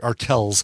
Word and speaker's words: Arkells: Brand new album from an artist by the name of Arkellis. Not to be confused with R. Kelly. Arkellis is spelled A-R-K-E. I'm sorry, Arkells. A-R-K-E-L-L-S. Nice.